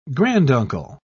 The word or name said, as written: granduncle